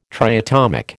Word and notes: triatomic: American English

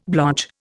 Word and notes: blotch: Indian

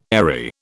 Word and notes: airy: British English

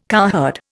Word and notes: cowherd: Indian

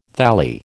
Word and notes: thalli: American English

